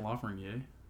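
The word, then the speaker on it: Lafrenière: Isn't Lafreniere? – It's very dependent, but I believe he's from Quebec, so that's the, like, French-Canadian pronunciation. I'm just trying to, like, respect.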